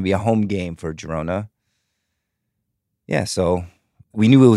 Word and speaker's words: Girona: Girona, am I pronouncing it right? – Yep.